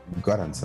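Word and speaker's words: Göransson: ok, entonces en otras noticias ya se confirmó que Ludwig Gorrach, eh, nunca sé pronunciar su apellido, ¿es Goranson? ¿O Goranson? Goranson.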